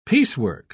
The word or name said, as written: piecework